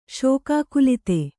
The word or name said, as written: ಶೋಕಾಕುಲಿತ